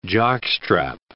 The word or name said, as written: jockstrap